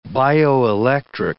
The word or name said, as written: bioelectric